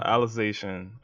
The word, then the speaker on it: Alicization: So that's the start, basically the start of Aciliacization or whatever it's called. – Alicization. Yeah, I think I said it right that time.